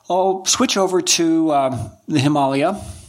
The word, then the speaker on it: Himalaya: So if you are in Nepal, you would call it Himalaya. In the United States, we have this way of using the vowel A in two different pronunciations. So we'd say Himalaya.